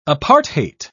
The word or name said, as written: apartheid